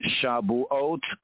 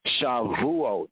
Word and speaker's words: Shavuot: Shabuot, called by other Yehudi, other Jews, would enunciate it, pronounced as Shavuot.